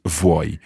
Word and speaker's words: vuoi: Yeah, it's like, if you think of it as V-W-O-Y in English. – Okay. – It's not a good idea, but that might help you think about how you would pronounce vuoi.